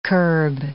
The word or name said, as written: kerb